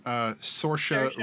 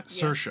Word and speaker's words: Saoirse: It, of course, stars, I always forget her name, Saoirse Ronan. And then Saoirse.